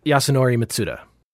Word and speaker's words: Yasunori Mitsuda: Yotsunori Mitsuda. – It was Uematsu's birthday recently. – Oh, it was yes, it was Uematsu's birthday, but this is of course Yasunori Mitsuda, one of the handful of really top notch like video game composers like Koji Kondo, Nobuo Uematsu, and Grant Kirkhope.